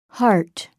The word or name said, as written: heart